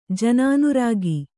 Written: ಜನಾನುರಾಗ

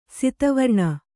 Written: ಸಿತವರ್ಣ

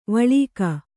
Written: ವಳಕು

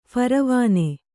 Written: ಫರವಾನೆ

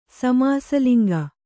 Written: ಸಮಾಸಲಿಂಗ

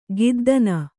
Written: ಗದ್ಧಣ

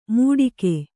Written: ಮೂಡಿಕೆ

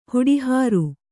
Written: ಹುಡಿಹಾರು

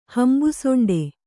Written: ಹಂಬುಸೊಂಡೆ

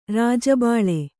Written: ರಾಜಬಲೆ

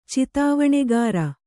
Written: ಚಿತಾವಣೆಗಾರ

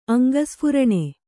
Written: ಅಂಗಸ್ಫುರಣೆ

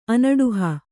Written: ಅನಡುಹಿ

